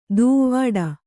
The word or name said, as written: ದೂವ್ವಾಡ